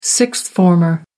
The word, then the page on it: sixth-former: American English